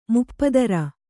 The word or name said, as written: ಮುಪ್ಪದರ